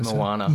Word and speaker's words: Moana: Not Manoa. How do I Moana. – Say it? – Moana.